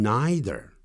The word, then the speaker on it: neither: 另外，既不怎么样，也不怎么样，neither 点点点，nor 点点点，neither，但也有人念成 neither，或者是刚才的 either 也有人念成 either，啊，这样的发音，这个 ei 在德文里头念成 i，像 Einstein，啊，这样哈，爱因斯坦这样。